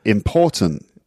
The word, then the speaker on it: important: But important. – Mm. – So in English, the stress is on the second syllable.